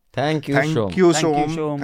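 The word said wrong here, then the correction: Som: थैंक यू so much यहाँ से नाम से और आपने अपने नाम के उच्चारण के बारे में लिखा है एस ओ एम है लेकिन उच्चारण शोम होगा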